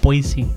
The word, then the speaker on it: Boise: Boise.